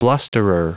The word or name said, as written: blusterer